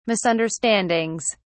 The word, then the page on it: misunderstandings: British English